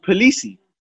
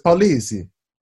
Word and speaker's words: Polisi: But his name's pronounced Polisi. Polisi. – Yeah, because he's Italian, so it's Polisi.